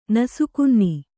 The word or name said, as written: ನಸುಕುನ್ನಿ